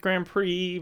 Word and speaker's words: Grand Prix: Grand, you don't pronounce the D.